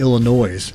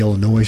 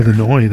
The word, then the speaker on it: Illinois: Matchbox 20 performed at the Illinois State Fair on Sunday. It's not the Illinois State Fair. – Right. – It's the Illinois State Fair. – Illinois, that's right.